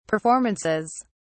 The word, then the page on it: performances: British English